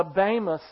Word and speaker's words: Bema: which is the Bema seat. That's the way you pronounce it, even though it's spelled B-E-M-A. That's an eta instead of an epsilon. Sounds like an A.